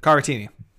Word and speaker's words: Caratini: Caratini.